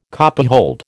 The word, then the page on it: copyhold: American English